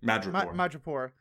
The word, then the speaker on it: Madripoor: I have been, I realized, like, until they briefly mentioned Madripoor. – Madripoor, am I pronouncing that right? – Madripoor.